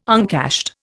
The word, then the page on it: uncashed: Indian